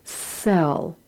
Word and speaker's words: cell: When the letter C precedes the vowel E, it makes an S sound. So C-E-L-L is pronounced sell, as is S-E-L-L.